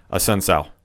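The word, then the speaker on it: Assunção: After that, the co-main event was was the final bantamweight matchup of the night between Cody Garbrandt, who defeated Rafael Asakao, if I mispronounced that, who shot clock cheesed the knockout at 4 minutes and 59 seconds in the second round. – A Sun Sal.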